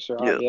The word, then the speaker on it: Surratt: Third round with pick 96, we got Chaz Surratt. Did I pronounce that correct? Surratt, yeah.